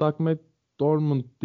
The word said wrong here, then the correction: Doug McDermott: diye mi okunuyor o çocuk? Doug McDermott.